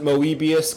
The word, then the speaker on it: Moebius: Some people pronounce it Moebius, because whereas normal Mobius is spelled M-O-B-I-U-S, evil Moebius is spelled M-O-E-B-I-U-S.